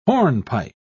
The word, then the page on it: hornpipe: British English